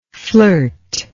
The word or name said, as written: flirtingly